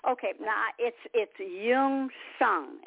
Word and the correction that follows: Myung Sung: I didn't want to correct you before, but it's Myung. You do pronounce the M, but the Y, it sounds like Myung. Myung Sung.